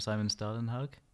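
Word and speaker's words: Simon Stålenhag: Simon Stalenhag? – Yes.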